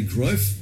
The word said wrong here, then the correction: Cruyff: Johan Cruyff. – Thank you, because it, you can't. – Cruyff. – You just can't. – Cruyff. Yeah, that's Cruyff.